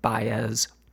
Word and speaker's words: Baez: I pronounced it the way that it's spelled, but apparently it is pronounced Baez.